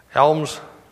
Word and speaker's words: alms: Do the children understand what alms? Am I saying it right? Is that how it's pronounced? Somebody can help me out. Alms. Alms. Thank you. It wasn't sounding quite right in my ear, so alms.